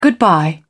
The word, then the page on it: goodbye: British English